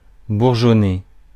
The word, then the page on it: bourgeonner: IPA: [buʁ.ʒɔ.ne]; French; France